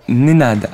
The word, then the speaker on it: Nénad: Non, Nénad avec un N. – N comme Noémie ? – Oui, N-E-N-A-D. – Deux. – Je m'appelle Shin. – Pardon ? Épelé, s'il vous plaît. – S-C-H-I-N.